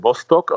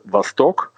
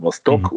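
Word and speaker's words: Vostok (Восток): Nee, äh, also die Leute sagen Bostock, also einfach äh, so. richtig wäre Vostok, mhm. aber das sagen nur die wenigsten, beziehungsweise die, die wissen, dass das russische Wort tatsächlich ja. die Betonung auf der zweiten Silbe hat, Vostok, mhm.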